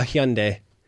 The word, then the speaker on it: Hyundai: Exactly, a Hyundai. That's how you say it properly. – You mean a Hyundai? – No, it's a Hyundai. It's Korean. I lived in Korea for 2 years, Jeremy.